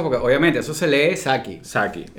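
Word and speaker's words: Psaki: ¿Cómo se dice? Saki. Saki. Saki. – Saki. – O sea, no, sé cómo. – Porque tú es sabes que P.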